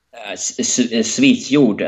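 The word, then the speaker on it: Svíþjóð: Is that how you pronounce Sweden up there? – Svithjod, and this is, as I Svithjod. Oh. – yeah, we talked about last time. This is the how the Icelands are calling Sweden today, a bit like this. This is the very similar to how the Icelands call Sweden today.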